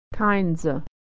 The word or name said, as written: kindsa